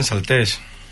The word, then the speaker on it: Saltés: Lo hace muy bien y nos cuenta la historia de Salte un poquito con cuento. – Tiene acento en la E, ¿eh? Saltés. – Salté, eso es, Salté. Lleva razón, pero es Salté.